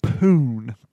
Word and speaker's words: Poon: Her last name is what? – Poon. P-O-O-N.